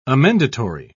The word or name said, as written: amendatory